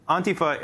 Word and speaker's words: Antifa: Yes, well, it's pronounced Antifa. The emphasis is on the first syllable, and it's pronounced more on than and, so Antifa is commonly mispronounced. But Antifa, of course, is short for anti-fascist.